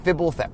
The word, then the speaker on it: Fibblethip: By the way, it's pronounced Fibblethip. So if you just kind of, there's no vowels in the homunculus' names, but in this particular one, if you just kind of assume there's eyes, anyway, Fibblethip.